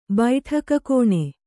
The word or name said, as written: ಬೈಠಕಖಾನೆ